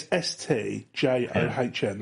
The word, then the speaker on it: St. John: It's STJOHN. That's pronounced St. John.